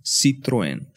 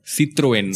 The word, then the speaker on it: Citroën: C- sorry, the pronunciation is Citroën. Citroën. Citroën. I learned that from them this time.